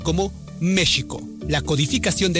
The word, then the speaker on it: México: De hecho, la Real Academia Española reconoce que la pronunciación correcta en español es con el sonido de la J, México, pero recomienda mantener la escritura oficial México con X, por razones históricas, culturales e identitarias.